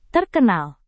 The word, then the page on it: terkenal: indonesian